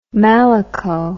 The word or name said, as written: malaco